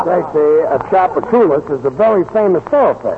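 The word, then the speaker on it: Tchoupitoulas: Chappaculas. T-C-H-O-U-P-O-T. – T-C-H-O-U-P. Oh, no. H-O-P-A-double-T. – No. A-S-H-O-T.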